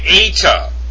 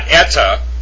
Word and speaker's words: eta: It's not eta, it's eta. And that again is number C. So in the alphabet, it's pronounced zeta, eta, theta. And we'll go over that. So eta is not pronounced eta, it's eta, it's long.